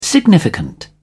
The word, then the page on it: significant: British English